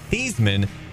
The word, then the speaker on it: Theismann: It says here that Theismann, whose last name was actually pronounced Theismann, recounted in 2007 that it was Notre Dame publicity man Roger something that insisted he change the pronunciation to rhyme with Heisman.